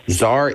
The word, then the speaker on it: tsar: Tsar is correct. You have multiple silent letters you can choose from here. There's the T when you spell it with an S. There's the C when you spell it with the Z.